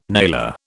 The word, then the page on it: nailer: British English